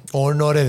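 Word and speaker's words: Honoré: Honore de Balzac. ¿Cómo se dice Honorato? Sí, Honorato está castellanizado, está según yo. – Es Honore, sí, es en honore. Realidad se debería ser Honore.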